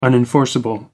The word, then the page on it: unenforceable: American English